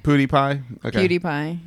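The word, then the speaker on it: PewDiePie: Which, by the way, okay, so like, so I have, so a excuse me, I have a surprisingly limited knowledge of Pootie Pie. Am I saying it right? – Yeah, Pootie Pie.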